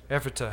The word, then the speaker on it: Ephrata: This Peter Miller was a Baptist pastor, and he lived in Pennsylvania, a town called Ephrata, if I said that right. Ephrata, had it right the first time.